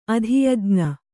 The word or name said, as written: ಅಧಿಯಜ್ಞ